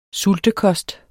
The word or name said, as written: sultekost